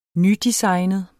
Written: nydesignet

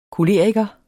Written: koleriker

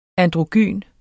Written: ANDROGYNT

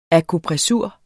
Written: akupressur

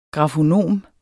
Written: grafonom

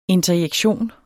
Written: interjektion

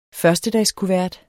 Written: førstedagskuvert

